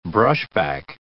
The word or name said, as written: brushback